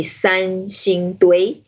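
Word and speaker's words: Sanxingdui: Yeah, this is called, I know the Chinese name is pretty hard to pronounce. It's called the San Xing Dui. It's quite tricky.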